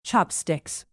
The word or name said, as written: chopsticks